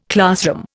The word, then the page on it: classroom: Indian